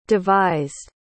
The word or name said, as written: devised